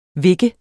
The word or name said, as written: vikke